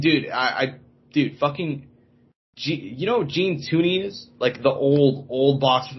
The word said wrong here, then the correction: Tunney: Okay, Gene, I may be pronouncing his name wrong. Gene Tunney.